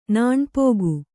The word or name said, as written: ನೆನಪುಗ